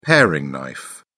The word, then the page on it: paring knife: British English